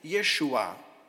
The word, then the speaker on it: Jeshua: Und Jesus ist ja eigentlich auch ein hebräischer Name, wird ausgesprochen Jeshua.